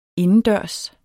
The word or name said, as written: indendørs